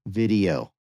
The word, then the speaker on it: Vidyo: The next one is called Video, but Video is spelled V-I-D-Y-O, Video.